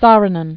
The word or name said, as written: Saarinen, Eero